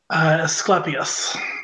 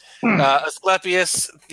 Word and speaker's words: Asclepius: There you found a local priest of, and I can never get it right. – Asclepius. – Thank you. Asclepius, the god of healing.